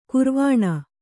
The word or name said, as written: ಗೌರೀವೀಣೆ